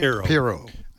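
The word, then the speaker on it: Pirro: Piro.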